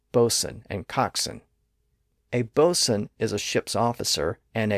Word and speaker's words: boatswain: But again, that's the second syllable, and it's an unstressed syllable, and in that phonetic environment, the W sound was lost near the beginning of that syllable, giving us modern-day bosun and coxswain.